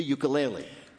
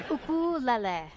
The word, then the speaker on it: ukulele: the ukulele. – Peter's first question to Taimane How the hell do you pronounce ukulele? – Ukulele.